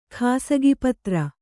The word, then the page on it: ಖಾಸಗಿಪತ್ರ: khāsagi patra